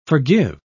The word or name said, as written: forgive